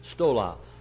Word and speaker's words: stola: Stola. Now there are two words. One is stola and one is stole. S-T-O-L-A or S-T-O-L long A. Stola, short A. Stole, long A.